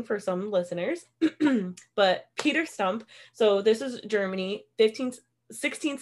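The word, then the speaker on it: Stumpp: Sometimes you'll even see it spelled or said as Stub. But often he was referred to as Peter Stump.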